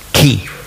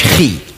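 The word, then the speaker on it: Ki (χ): Chi, das hat man eigentlich ausgesprochen, Ki. Nicht Schweizerdeutsch, Chi. Also nicht Chuchichastli, in dieser Art, sondern eben Ki.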